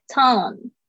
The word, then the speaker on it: tongue: tongue 这个 ng 是一个后舌音，嗯，tongue，所以呢，大家讲注意一下那个 tongue 的发音。